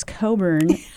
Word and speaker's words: Cockburn: And, you know, you also introduced me to Bruce Coburn. Thank you for the pronunciation, folks. It's spelled C-O-C-K-B-U-R-N, but it's Coburn.